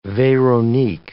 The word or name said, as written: Veronique